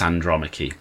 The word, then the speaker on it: Andromache: And I was like, surely it's Andromache, because Andromache is a character in Greek mythology. – Yeah, that's where I know the name from. That's why I pronounce it the way it does.